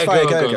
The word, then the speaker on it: Viagogo: Is it Viagogo or is it Viago? I think it's Viagogo. That's it.